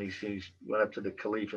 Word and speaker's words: Caliph: There was a Salif, am I saying that right? Salif, a Caliph, I think it's Caliph.